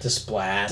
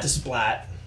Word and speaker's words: Desplat: Desplat. Desplat. Remember Stephen Platt, the uh, remember Stephen Platt, the, the artist, artist, on Moon Knight.